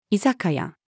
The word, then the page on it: 居酒屋: female